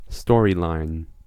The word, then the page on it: intrigue: France; /ɛ̃.tʁiɡ/